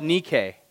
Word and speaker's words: Nike: Now, it's actually pronounced Nike in Greek. And it comes after a goddess called Nike, known to be the one who carries off the victory.